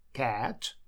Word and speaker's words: cat: Буква A здесь читается широким звуком E, с широко открытым ртом. Cat.